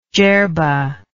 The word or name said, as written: jerba